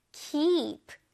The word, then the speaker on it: keep: 이거 발음할 때 keep가 아니에요. 이가 두 개이니까 길게 발음해줘볼게요. 준비됐어요? keep, keep.